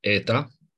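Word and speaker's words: Eta (ήτα): Eta, care este E, deci mergem pe pronunția, o să vorbim și despre asta rapid, pronunția erasmică, pronunția care se folosea în greaca veche, nu în greaca bizantină și greaca modernă, unde e ita aici.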